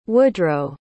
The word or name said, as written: woodroe